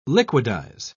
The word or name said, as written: liquidize